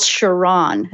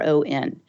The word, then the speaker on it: Chiron: In astrology, there's a planet something called Chiron. That's how I pronounce it. It's Chiron.